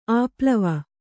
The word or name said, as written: ಆಪ್ಲಾವ